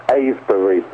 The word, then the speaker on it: Avebury: First, before we go to Avery, and I believe you said Avery, right? – Avery, yes. – How do you spell it? – A-V-E-B-U-R-Y.